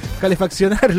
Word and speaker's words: calefaccionar: también para calefeccionar los hogares. Sí, lo dije mal, me dicen. Calefaccionar los hogares, claro.